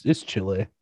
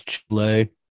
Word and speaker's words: Chile: No, it's Chile. It's like when Forrest Gump's cold, it's Chile.